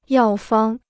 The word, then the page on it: 药方: yào fāng